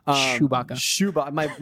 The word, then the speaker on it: Chewbacca: Is that how you say it? The Chewbacca mask. Sorry. – Chewbacca.